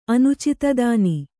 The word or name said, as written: ಅನುಚಿತದಾನಿ